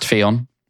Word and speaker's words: Ffion: It is pronounced Fion. – For Fion. – F-F-I-O-N.